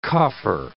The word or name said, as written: coffer